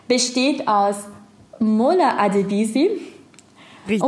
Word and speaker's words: Mola Adebisi: Äh, Mola Adebisi komplett richtig ausgesprochen.